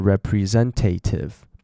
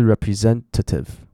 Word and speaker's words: representative: 那 representative 有时候大家会不小心念成 representative，不是，是 representative， OK， representative。